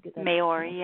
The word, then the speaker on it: Maori: Maori, yeah, you're saying it right.